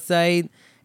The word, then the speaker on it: Saeed: I was at one time in my life, but now I pronounce it like the way I say it is Abdullah Saeed.